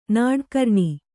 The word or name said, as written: ನಾಡ್ಕರ್ಣಿ